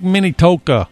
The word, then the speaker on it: Minnetonka: Lake Minnetonka, or Minnesota, Lake Minnetonka, right, something like that. – Minnesota. – Yeah. How do you say it? – I say it's Lake Minnetonka.